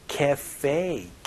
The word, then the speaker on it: cafe: 카페가 아니고 cafe 이렇게 발음하니까 혹은 커피숍이라고 발음해주시면 좋겠네요.